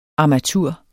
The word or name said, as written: armatur